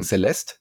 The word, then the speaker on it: Celeste: Wie spricht man es eigentlich aus? Lass uns erstmal einigen. Celeste, würde ich sagen. – Celeste, oder? Würde ich auch sagen. Also das E hinten ist stumm.